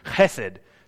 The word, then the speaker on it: chesed: The Hebrew word, if you were to spell it, is H-E-S-E-D. But it's one of those real Jewish Hebrew words that you have to say like you're clearing your throat, right? And it's chesed.